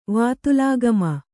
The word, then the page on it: ವಾತೂಲಾಗಮ: Kannada